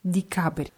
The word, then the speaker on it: декабря: Wird mit je geschrieben, aber das je wird eher wie i ausgesprochen, weil es unbetont ist. Die Cabre.